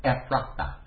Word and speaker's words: Ephrathah: "But you, Bethlehem Ephrathah," that's how to pronounce it, Ephrathah. I worked on that for a while because that's a toughie.